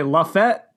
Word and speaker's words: Lafayette: Lafayette. – Apparently, we're both saying it wrong. Apparently, the people in Alabama say Lafayette, I think. So we were both saying it incorrectly.